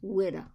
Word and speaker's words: Whydah: The museum exhibits are focused on the pirate ship Whydah, pronounced W-I-D-D-A-H, Whydah. That's typical New England.